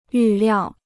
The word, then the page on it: 预料: yù liào